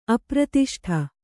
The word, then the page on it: ಅಪ್ರತಿಷ್ಠ: apratiṣṭha